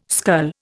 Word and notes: scull: Indian

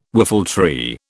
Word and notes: whiffletree: British English

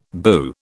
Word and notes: boo: British English

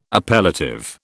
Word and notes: appellative: British English

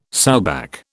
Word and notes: sowback: British English

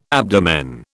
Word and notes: abdomen: British English

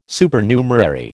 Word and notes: Supernumerary: American English

